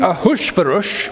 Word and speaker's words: Ahasuerus: King Ahasuerus, which is how you pronounce it in Hebrew, and I will, in the sermon, normally pronounce his name in that way.